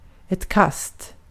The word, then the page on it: throw: American English; [θɻoʊ]; English